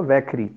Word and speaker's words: वैखरी: इसको हम लोग कहते हैं वैखरी इसका प्रोनाउंसिएशन होगा वैखरी